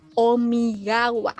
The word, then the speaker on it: Omigawa: Bueno, a Maka Aldran la interpreta Shiaki Omigaya, Omigawa, perdón, Omigawa, me cuesta pronunciar la, la W.